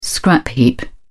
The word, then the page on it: scrap heap: British English